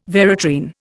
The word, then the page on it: veratrine: Indian